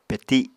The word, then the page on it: peti: Apatani